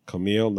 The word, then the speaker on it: Kumail: And Kumali, and I practice, and I even listen to this. I'm like, how do I pronounce his name? – Kumail Nanjiani.